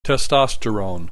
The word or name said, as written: testosterone